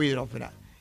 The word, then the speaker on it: hidrosfera: De todas ellas, solo la palabra atmósfera es esdrújula. Las demás son graves y llevan el acento sobre la sílaba fe. Estratosfera, ionosfera, biosfera, litosfera o hidrosfera.